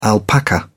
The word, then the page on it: alpaca: British English